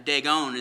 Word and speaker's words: Dagon: Dagon is how you pronounce it.